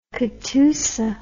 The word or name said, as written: katusa